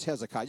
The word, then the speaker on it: Hezekiah: Is it Hezekiah, Hezekiah, Hezekiah? We, Mom, it's just Hezekiah.